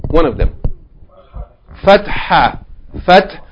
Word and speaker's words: fatha: It's spelled here F-A-T-A-H. It should be spelled F-A-T-H-A-H. Fatha. Okay, Fatha. And this way of writing it here throws off the pronunciation. So be careful, huh? It's Fatha. Fatha. Okay, so this is the first one. It's the sound of A, Fatha. Fatha. Or we can say, if we pronounce the last letter of the Tarmabutta, Fathatun. Fathatun. But we say, if we don't pronounce the ending, we say Fatha.